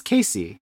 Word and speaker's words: K-Ci: Number 3 is All My Life by K-C. – K-C? No, it's Casey. Casey. – Yeah, well, it's I know it's pronounced Casey, but when I was looking the song up, it is spelled out K-C-I.